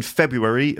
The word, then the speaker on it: February: How do you say that month? Because the spelling is pretty weird. F-E-B-R-U-A-R-Y. But it's actually pronounced February. I think there's several ways to say this month, okay? February, Feb-U, like me and you, February, or February, February. So February, four syllables, or February, three syllables, okay?